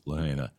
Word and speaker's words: Lahaina: We do have something special that we're going to do today, and it has to do with, I guess, you know, we got to get our pronunciation right. Lahaina. It's I know Lahaina Luna Drive, so yeah, I guess it is Lahaina.